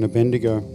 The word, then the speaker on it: Abednego: When Pastor Lee started the meeting and he said, this feels like Shadrach, Meshach and Abednego.